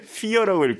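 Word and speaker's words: fire: 파이어인데 피어라고 읽고 4예요.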